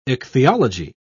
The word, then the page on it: ichthyology: British English